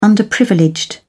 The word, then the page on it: underprivileged: British English